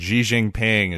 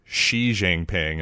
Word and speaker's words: Xi Jinping: and Xi Jinping is just gone forever, or Xi Jinping, as I've heard it annoyingly pronounced lately.